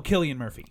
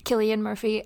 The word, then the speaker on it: Cillian Murphy: Oh, Cillian Murphy. – Cillian Murphy.